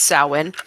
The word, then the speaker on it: Samhain: They call him Sam for Samhain, but it's Samhain.